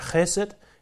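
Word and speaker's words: chesed (חסד): Det er et ord, der på hebraisk udtales noget i retning af chesed, eller H-E-S-E-D, hvis vi skal oversætte det til vores bogstaver.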